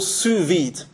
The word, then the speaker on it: sous vide: Something called sous vide. It's something. Don't try and spell it. It's a French word. Sous vide. S-O-U-S.